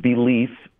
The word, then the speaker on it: Beleaf: And that is, there's a new wine called Belief Cabernet from the Herzog series. From Oxnard, California. – Spelled like the word belief? – No, it's spelled like B-E-L-E-A-F. Okay. It's kind of a play on words.